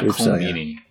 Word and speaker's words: Colm Meaney: Is it Colm? Colm Meaney? – Yeah, Colm Meaney.